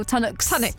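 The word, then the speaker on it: Tunnock's: I've gone for the classic tunnock tea cakes. Is that how you pronounce it? Tunnock or tunnocks? – Tunnocks.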